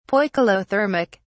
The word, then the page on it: poikilothermic: British English